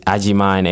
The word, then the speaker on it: Agyeman: And you can also follow me on Facebook, Nana, N-A-N-A. Last name is Ajiman, A-G-Y-E-M-A-N. And also follow me on Snapchat, at Nana Man. So it's spelled N-A-N-A underscore M-A-N.